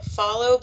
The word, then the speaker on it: Fahlo: Well, my cousin got me this cute, like, it's the brand or the name is called like Follow, but it's spelled F-A-H-L-O.